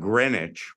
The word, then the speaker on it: Greenwich: Then you've got Greenwich, where you just leave the W out.